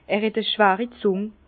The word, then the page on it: er het e schweri Zùng: Bas-Rhin; Herrlisheim